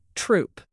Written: troupe

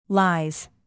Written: lies

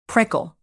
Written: prickle

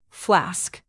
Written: flask

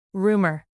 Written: rumor

